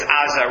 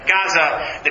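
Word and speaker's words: Gaza (עזה): You know that in Hebrew it's Gaza rather than Gaza. That begins with the guttural letter ayin, which is pronounced in the throat,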